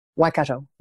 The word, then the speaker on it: Waikato: Waikato, yes, pronounced Waikato.